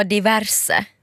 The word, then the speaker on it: diverse: Jag fick också veta att jag säger diverse fel. – Vad betyder det? – Det ska tydligen vara diverse. – Diverse, ja sant.